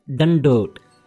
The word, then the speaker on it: dandot: The word is dandot. Theek. – Dandot? – You are saying a dandot. Dandot. It's a not a dadda. It's a dadda. Dandot. Dadda, na? – Dandot.